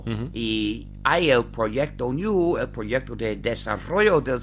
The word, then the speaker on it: GNU: Se escribe GNU, en inglés se pronuncia GNU, pero en castellano se pronuncia GNU.